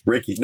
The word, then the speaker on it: Reiki: Reiki. No, you said it right. – I did. – Yes. – Okay. Yeah. So it's Reiki. – Reiki. Yeah. R-E-I-K.